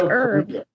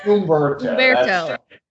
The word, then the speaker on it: Humberto: It's like Herb. The H is silent. – Humberto.